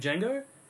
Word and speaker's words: Django: I'm also going with Django. – The D is silent.